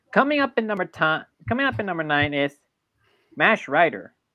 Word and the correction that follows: Mach Rider: It's Mach Rider, not MASH.